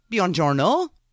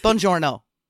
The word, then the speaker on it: Buongiorno: Buongiorno. I just pronounced that completely wrong. Buongiorno. That's better.